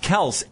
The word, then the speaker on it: Kelce: Because everywhere he'd go, it'd be Kelce, Kelce, Kelce. – Okay. – Even though it's pronounced Kelce, and he just gave up.